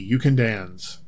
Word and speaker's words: Ukandanz: And Ukandans, I assume, is a pun, but it may not be because it's an Ethiopian band. Maybe it just sounds like a pun. It's spelled U-K-A-N-D-A-N-Z, Ukandans.